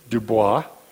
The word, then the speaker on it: Dubois: He may have wintered with the crow near present-day Dubois. I guess the American pronunciation is Du Bois, right?